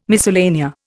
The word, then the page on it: miscellanea: Indian